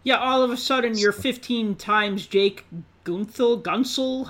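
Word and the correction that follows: Guentzel: How do you say his name? – Gensel.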